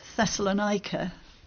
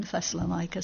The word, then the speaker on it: Thessalonica: Now Paul visited Thessalonica, as we were taught to pronounce it when I was young, Thessalonica. Some people say Thessalonica now, but I'm going to persist with Thessalonica.